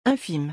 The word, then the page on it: infime: French